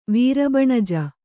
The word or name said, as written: ವೀರಬಣಜ